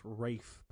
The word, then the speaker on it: Rafe: I do like Raffi Spall. Although I think it's pronounced Rafe, but that's too close to rape. So we're going to say Raffi.